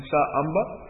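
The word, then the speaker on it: Samba: And Sa Amba. It's a long A. Sa Amba.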